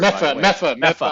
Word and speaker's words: Medford: It's not Medford. – Mepha. I know.